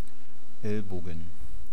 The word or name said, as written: Elbogen